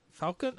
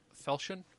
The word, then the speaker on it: falchion: Falcon? How do you say that correctly? – Falchion, I believe.